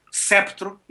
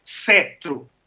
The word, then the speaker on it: sceptro: E, no entanto, a pronúncia portuguesa da palavra sempre foi sceptro e nunca cetro.